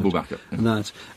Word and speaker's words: Abu Bakr: And this was a great friend of Muhammad's, Abu Bakr. Is that how you pronounce it? – Abu Bakr.